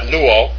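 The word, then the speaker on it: luo (λύω): All right, so number two, the simple present use of luo. That's how you pronounce that, luo.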